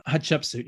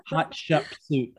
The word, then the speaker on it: Hatshepsut: Am I saying that right? – Yeah, Hatshepsut. You can always remember it if you remember a hot chicken soup. – Yeah, it sounds like that. Hatshepsut, hot chicken soup, Hatshepsut.